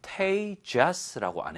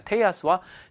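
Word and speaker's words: Tejas: 근데 이게 제가 읽을 때 Tejas라고 안 했죠. 이왜 그러냐면 스페인어에서는 J가 히읗 발음으로 납니다. 그래서 Tejas가 아니고 Teos가 되겠습니다. Teos와 Tara를 데리고 숲속을 통해서 이제 데리고 쭉쭉쭉 가고 있었어요.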